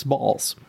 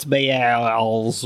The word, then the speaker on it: Baals: Now, his last name fairly is spelled B-A-A-L-S, but in the Indiana funk of accent, which I'm not casting any shade upon, that's Balls. – That's Balls, I think, no matter what. – Well, somebody could be like, no, it's Bales or some weird pronunciation, but it's Balls.